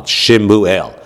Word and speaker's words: Shmuel: But a lot of people pronounce it as Shmuel. Right? Shmuel, that's how you get Shmuley, right? The nickname for a kid, a man named, or a boy named Shmuel, is not Shmueli, it's Shmuley. How do you get the Shmuley? You get the Shmuley because the name is pronounced Shmuel, right? You sort of slur it.